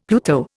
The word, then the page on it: Pluto: Indian